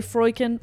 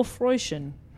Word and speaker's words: Freuchen: I've also seen it pronounced a few different ways. I'm going to say Freuchen or Freuchen. – No, I like Freuchen.